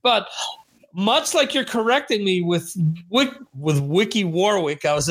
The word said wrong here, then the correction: Ricky Warwick: about to say, uh, Ricky Warwick.